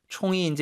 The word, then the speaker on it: 冲: 총이 이제 이게 일성으로 발음할 때 있고 사성 발음할 때 있는데 사성, 총, 셉니다.